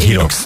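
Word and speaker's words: HYROX: Beltéri Hirox versenyen. Ezt így hívják, ez a sportágat, hogy Hirox? – Szerintem hívjuk így, nálunk az még annyira... – Magyarországon nem... én eddig csak így hallottam kiejtve. – Hogy Hirox.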